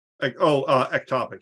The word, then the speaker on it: ectopic: Epitopic. I always say it wrong. Epitopic, is that it? – Ectopic, yeah.